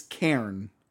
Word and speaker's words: cairn: You said Karen, like the meme. – Oh. – Yeah. Karen. – I've been saying that wrong. – Oh, my boy. Entire life. – Oh. It's Cairn.